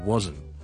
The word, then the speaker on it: wasn't: 或者 wasn't it? 咁你今集讲嘅咧就系 isn't 同埋 wasn't 呢两个字。要特别留意嘅咧就系佢个发音上咧系呢两个字咧都系中间系个 z 音嚟嘅。